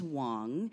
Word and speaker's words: Wong: So my last name is Wong. Mm-hmm. It rhymes with things like dong and schlong right. – and long.